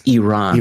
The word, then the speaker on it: Iran: Saying, you say this, it's Iran.